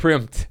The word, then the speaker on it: primped: I pimped and groomed them myself. I said that wrong. What did I say? – Primped? – Primped. – Yeah. – I said pimped.